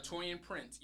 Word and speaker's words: Taurean Prince: I'm sorry, I always say his name wrong. Ter- um, Teron Prince. – Uh, Taurean Prince, yep.